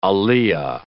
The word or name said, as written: aliya